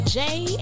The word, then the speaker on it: Jaie: This is your girl, Jay, and welcome to the Jaie Spot, and that Jay is spelled J-A-I-E.